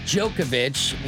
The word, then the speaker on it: Djokovic: Well, the Wimbledon situation over the weekend was surprising to say the least with Novak Djokovic, with the silent D, if you ever wonder.